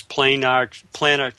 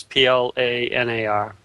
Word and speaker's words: planar: But basically what we have up till now is planar transistors, PLANAR. I think you pronounce it planar.